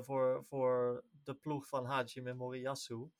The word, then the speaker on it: Hajime Moriyasu: Hajime Moriyasu.